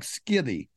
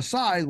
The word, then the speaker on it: scythe: So a scythe was simply a blade that you swung, you know, with your, if you're wondering how to spell it, it looks like skithy. All right. So it's S-C-Y-T-H-E, pronounced scythe.